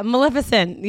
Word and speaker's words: Maleficent: Oh yeah, Maleficent.